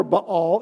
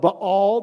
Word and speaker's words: Baal: By the way, if you pronounce this Baal or Baal, it's fine. Keep right on doing it. I revert back to Professor Williamson's Hebrew class, so sorry about that. Most of the time when you see what was a Hebrew word with 2 of the same vowel slammed together, they're both supposed to be pronounced because there's a letter in Hebrew that, anyway, never mind.